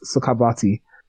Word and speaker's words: Sukhavati: I believe it's pronounced Sukhavati in Buddhist tradition.